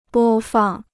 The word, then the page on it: 播放: bō fàng